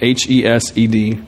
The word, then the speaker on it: hesed: It's a Hebrew word uh, called hesed, H E S E D. Uh, there's probably some sort of like guttural thing in there that I won't subject you to uh, in pronouncing it. Let's just let's go with hesed.